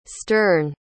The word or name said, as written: sterne